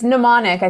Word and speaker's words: mnemonic: It's like a mnemonic. – A mnemonic. How do you even pronounce that? I need a mnemonic for how to pronounce mnemonic. I think it's mnemonic. I think it's like a silent M.